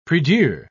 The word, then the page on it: prie-dieu: British English